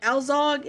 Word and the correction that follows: Alzog: It's, again, this is where it's spelled differently than it's pronounced. It's Altsok, Johannes Baptist, or Johannes Baptist Altsok.